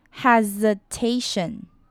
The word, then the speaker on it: hesitation: 最后我们看到 hesitation 呢，我们要注意到的是 s 不是发 s 的音，而是发 z 的 z 的音，hesitation。